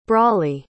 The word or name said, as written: brawley